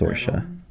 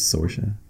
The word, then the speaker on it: Saoirse: It's Saoirse. People mispronounce it as Saoirse.